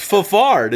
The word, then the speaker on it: Fafard: It's Fafard. It's F-A-F-A-R-D. Tell me how else to pronounce that. – Fafard. – Yeah, that sounds way better.